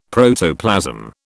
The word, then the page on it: Protoplasm: British English